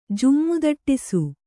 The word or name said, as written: ಜುಮ್ಮುದಟ್ಟಿಸು